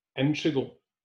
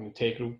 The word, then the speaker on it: integral: But he's absolutely integral, or integral, depending on your pronunciation.